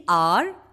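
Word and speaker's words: hour: എക്സാമ്പിൾസ് ഓഫ് ദി: ദി ആപ്പിൾ, ദി എലിഫന്റ്, ദി ഇങ്ക് പോട്ട്, ദി ഔൾ, ദി അംബ്രേല, ദി ആർ, H O U ആർ, മണിക്കൂർ. ഇവിടെ H consonant ആണെങ്കിലും ഉച്ചാരണ ശബ്ദം vowel സൗണ്ടായ ആ ആണ്. ഹെൻസ് ദി ആർ ആൻഡ് നോട്ട് ദ ആർ.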